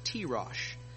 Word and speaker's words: tirosh: The Hebrew word for grape juice is pronounced tirosh.